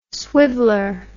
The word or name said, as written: swiveller